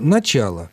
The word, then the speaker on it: начал: Значит, в инфинитиве начать, а в формах прошедшего времени для мужского рода начал, а не начал.